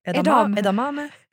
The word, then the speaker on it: edamame: Edamamebönor, uttalar man det så? Edame. – Jag tycker det där är jättesvårt. – Edamame.